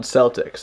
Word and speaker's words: Celtx: But first, I want to point your attention to a little screenwriting program that I use all the time called Celtics. That's spelled S... No, it's not. That's spelled CELTX.